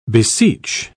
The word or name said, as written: beseech